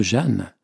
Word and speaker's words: Jehane: Moi, quand j'ai vu la manière aussi dont le nom était écrit par ma collègue, je prononce Jeanne, mais il s'écrit J-E-H-A-N-E, Jeanne, mais on le dit Jeanne.